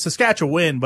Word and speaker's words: Saskatchewan: Because it's Saskatchewan, but for the song, it had to be Juan for rhyme with gone. So Saskatchewan? – Well, Corb Lund says long gone to Saskatchewan. – Juwan.